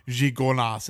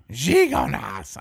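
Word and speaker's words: Gigondas: Gigonas. I see. I mispronounce everything because I talk like this. – Well, you think of gigolo and you just go with it.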